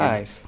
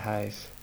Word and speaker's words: hajs: Ja, hur uttalas det? – Hajs. Hajs. Eller bethajs.